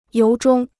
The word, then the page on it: 由衷: yóu zhōng